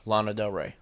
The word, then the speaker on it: Lana Del Rey: Lana Del Rey.